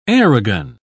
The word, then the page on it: aerogun: British English